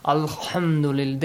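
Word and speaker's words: الحمد لله: And also, be careful, do not change the pronunciation of the letter ha to kh. Sometimes I've seen, okay, people who are pronouncing this, alhamdulillah, they're about to say kha, alhamdulillah. This is also wrong. Okay?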